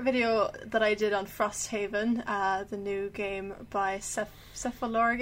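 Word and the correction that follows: Cephalofair: Games, is that how you say it? – Cephalopair, I think. – Cephalopair, yeah, I try.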